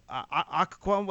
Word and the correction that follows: Öcalan: what the guy, the leader of the PKK? – Öcalan. – Sorry, I got his name wrong.